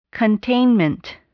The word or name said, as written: containment